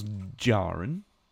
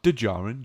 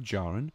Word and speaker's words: Djarin: As she tells Jaren, not Djarin, Jaren, the D is silent, the Mandalorians once waged war against the Jedi.